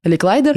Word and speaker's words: Ликлайдер: Э, Джозеф Карл Робнет, нет, да, ударение, Ликлайдер.